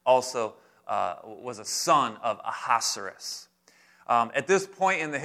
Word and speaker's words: Ahasuerus: And their king, it's kind of hard to pronounce, so I pronounce it Ahasuerus. That's how it helps me remember it. Aha, because it starts off with A-H-A. But that name, Ahasuerus, is a title for the king of Persia.